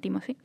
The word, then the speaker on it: Timothée: que en realidad creo que se dice Timothée, pero él dice, es que los americanos no son capaces de hablar en francés, entonces que me llamen Timothy.